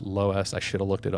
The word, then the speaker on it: Loess: Okay, according to the Google pronunciation, it's pronounced Loess, like Lois Lane, but it's L-O-E-S-S.